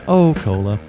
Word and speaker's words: Osceola: I say that right? Looks like Ocola. – Yep. – Ocola.